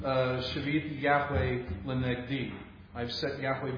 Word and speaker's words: shiviti: You would say in modern Hebrew, shiviti. I pronounce it shiviti, but modern Hebrew would be shiviti.